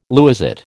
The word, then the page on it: lewisite: American English